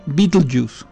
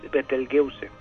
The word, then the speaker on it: Betelgeuse: Lo único que encontré es que no se pronuncia Betelgeuse como la película. – Bueno, eso ya sabemos. Yo creo que muy poca gente ya se acuerda de la película, pero investiguen al respecto y verán por qué la confusión, ¿no? De todas maneras, yo también estuve investigando y creo que la pronunciación correcta, lo cual no quiere decir que sea la más común, es Betelgeuse.